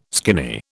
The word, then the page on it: skinny: British English